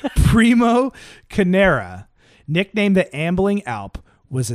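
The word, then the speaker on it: Carnera: Okay, because Primo Canero did I say his name right? No. Primo Canera, nicknamed the Ambling Alp, was a six foot five and a half be- foot beast.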